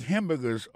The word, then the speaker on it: hamburger: N'a pas remarqué que dans le mot hamburger, ça a comme tu as dit, il y a trois syllabes là-dedans. Quand tu appuies et puis plus ça, c'est sous premier syllabe là.